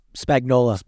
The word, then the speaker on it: Spagnuolo: Steve Spagnuolo? Is that how you say it? – Spagnola.